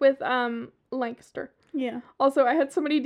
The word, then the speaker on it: Lancaster: I know you practice when you moved here how to say it the correct way. Lancaster, Lancaster, Lancaster.